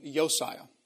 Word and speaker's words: Josiah: So Daniel would have been born under the kingship of the last good king of Judah, known as King Josiah, or more properly pronounced King Yosiah.